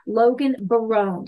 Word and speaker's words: Logan Barone: Well, everybody, I have a brand new person who I am getting to know who really is amazing right at the get-go, Logan Barone.